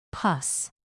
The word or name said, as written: pus